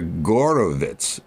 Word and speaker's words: Horowitz: In German, the G's are pronounced H's and the H's are pronounced G's. So it's Vladimir Gorovitz, we say Horowitz, and Alexander Gauck, it's spelled, but it's really Alexander Hauck.